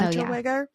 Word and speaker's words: Unterweger: And then Jack Unterweger, is that how you pronounce it? – I think so. Unterweger yeah.